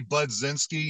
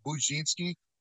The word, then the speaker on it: Budzinski: It's actually Budzinski. You know, some people call it Budzinski.